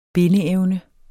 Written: bindeevne

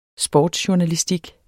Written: sportsjournalistik

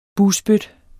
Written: bugspyt

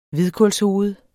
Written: hvidkålshoved

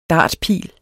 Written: dartpil

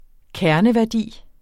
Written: kerneværdi

